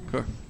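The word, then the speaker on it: cœur: Yeah, core. Like heart, core, heart.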